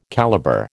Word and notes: calibre: American English

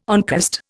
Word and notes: oncost: Indian

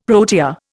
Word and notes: protea: Indian

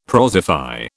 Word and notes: prosify: British English

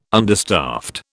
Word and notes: understaffed: British English

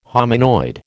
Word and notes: hominoid: American English

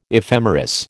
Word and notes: ephemeris: American English

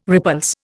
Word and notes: repulse: Indian

